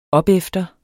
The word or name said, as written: opefter